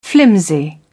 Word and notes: flimsy: British English

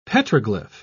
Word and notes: petroglyph: British English

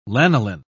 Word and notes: lanoline: British English

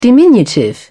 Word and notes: diminutive: British English

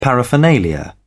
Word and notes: paraphernalia: British English

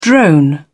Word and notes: drone on: British English

